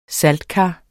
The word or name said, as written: saltekar